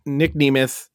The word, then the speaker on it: Nemeth: So they've been on this kick of, okay, Nick Nemeth signed over there. That's apparently how it's been pronounced, by the way. I didn't realize.